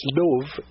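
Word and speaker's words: l'œuf: And so the French said the word "egg" is "louf," L-O-E-U-F, mm-hmm. – And that's where it became the Americans and English speaking people in England mispronounced it "louf," and that's where zero meant love.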